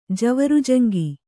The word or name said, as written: ಜವರುಜಂಗಿ